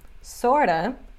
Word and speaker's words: sort of: E a pronúncia é outa, sorta e kinda.